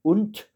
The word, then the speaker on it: und: Il y a au vers numéro 2, und, la conjonction de coordination qui veut dire et. Und, il faut bien le prononcer comme si c'était écrit UNT et non pas UND.